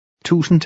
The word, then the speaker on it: tusind: The D is usually silent when it follows an N. – Tusind tak.